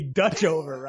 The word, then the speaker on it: Dutchover: Michael Duchover against Thomas Matisse. – It's got to be Dutchover, right? Duchover.